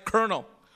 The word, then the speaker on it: colonel: But how have we, as English-speaking people, become so arrogant about spelling and grammar when we have words like kernel, pronounced K-E-R-N-E-L, but spelled C-O-L-O-N-E-L?